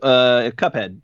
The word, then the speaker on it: Cuphead: Any of you guys, you probably have Cupman and Mughead. Am I saying that right? No, I botched it. – Cuphead, the Cuphead show.